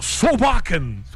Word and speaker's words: Solbakken: Inclusive ontem, né, durante a nossa visita ao estádio, a coletiva do Solbakken, é assim, né, que a gente vai ter que pronunciar, Solbakken. Vai ser assim que a gente vai falar, mas é Solbakken, né, o técnico da Noruega.